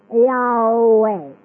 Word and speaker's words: Yahweh: And if you pronounce the name with all vowels, you will come out with Yahweh.